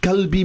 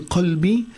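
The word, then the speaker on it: qalbi (قلبي): Kalbi means my hond. No, we don't want to say that. That is why it's so important that we go to ustaz and learn how to pronounce the different huruf.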